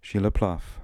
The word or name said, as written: Shila Plav